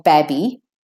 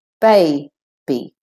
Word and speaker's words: baby: The A is at the end of the first syllable, bay, and so it can choose to say its name. We don't say babby, we say baby, baby.